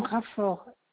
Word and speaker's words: Raffort: Alors, donc c'est Madame Raffort.